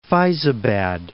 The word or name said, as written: Faizabad